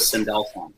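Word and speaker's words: Sandalphon: Above is Metatron, and below is Sindelfon. I don't even know if I pronounced the last one, but Sindelfon's below. – You did. So I Googled it.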